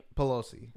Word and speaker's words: Pelosi: My dude left Nancy Pelosi's, how do you say her last name? – Pelosi.